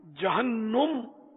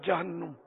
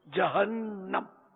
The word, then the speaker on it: جہنم: اللہ اللہ، جہنم یہ تلفظ غلط ہے، یہ غلط العوام ہے، عوام میں بکثرت ہے جہنم، نون پر زبر ہے، جہنم یہ قرآنی لفظ ہے جہنم.